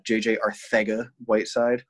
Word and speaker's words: J.J. Arcega-Whiteside: J.J. Arcega-Whiteside – no, I don't have a lisp. That's how he pronounces his name.